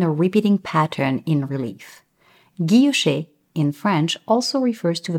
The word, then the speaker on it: guilloché: Today's Babble Bomb is guilloché, which is a type of decorative engraving.